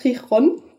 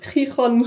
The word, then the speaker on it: Gijón: Und danach sind wir nach Chichon das spricht man so auch leider, ist sehr dumm.